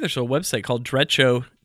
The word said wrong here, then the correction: Derecho: Derecho. it's like D E R and then Echo, right? That's how it's spelled.